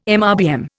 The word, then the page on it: MRBM: Indian